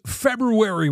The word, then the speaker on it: February: How's February working out for you? Do you get the R in there when you say it?